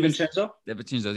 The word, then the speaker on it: DiVincenzo: DiVincenzo, whatever his name DiVincenzo.